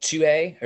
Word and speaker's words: Tuhey: If I'm pronouncing his last name right, is Jeff Tuhey, or at least it's spelled two, as in the number two and hey together, H-E-Y.